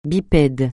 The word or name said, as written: bipède